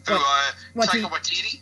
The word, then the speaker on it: Taika Waititi: Taika Waititi? – Yes.